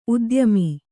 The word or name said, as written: ಉದ್ಯಮಿ